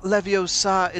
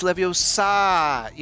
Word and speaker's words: Leviosa: It's not Leviosa, it's Leviosa. You have to say it correctly.